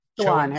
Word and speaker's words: Chowan: And the local Republicans in that district, this is per the, is it Chowan? – Chowan Harold, yeah.